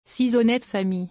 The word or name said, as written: six honnetes familles